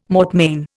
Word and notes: mortmain: Indian

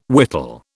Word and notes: whittle: British English

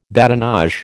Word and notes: badinage: American English

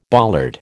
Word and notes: bollard: American English

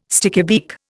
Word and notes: stickybeak: Indian

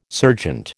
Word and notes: serjeant: American English

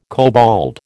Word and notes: kobold: American English

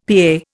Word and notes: PA: Indian

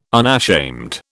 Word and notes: unashamed: British English

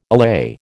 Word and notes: allay: American English

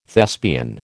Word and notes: Thespian: American English

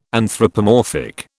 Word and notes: anthropomorphic: British English

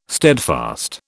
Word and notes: steadfast: British English

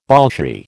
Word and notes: Bolshie: American English

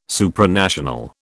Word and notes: Supranational: British English

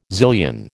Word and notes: zillion: American English

